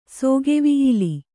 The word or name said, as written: ಸೌಗವಲ್ಲಿ